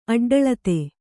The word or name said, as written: ಅಡ್ಡೆಳೆತ